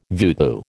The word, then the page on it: voodoo: American English